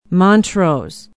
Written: montrose

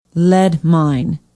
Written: Lead Mine

